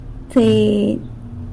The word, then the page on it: tseiny: fifteen